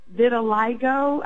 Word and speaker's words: vitiligo: My niece has vitiligo.